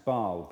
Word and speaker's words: Baal: He had sold out to this God who we've never heard of today, or most of us haven't, called Baal, or sometimes pronounced Baal, Baal or Baal, a local fertility god.